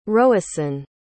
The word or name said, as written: roisin